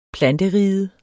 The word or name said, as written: planteriget